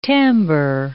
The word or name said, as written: timbre